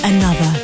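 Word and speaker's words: ANOTR: I found out another. They pronounced another featuring 54 Ultra.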